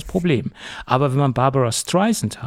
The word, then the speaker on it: Streisand: weil Siri hat es bis vor ein paar Monaten mit Z ausgesprochen und nicht mit S wie Sand, weil es heißt ja Barbara Streisand, wie der Sand am Strand, und das hat sie doch zutiefst geärgert.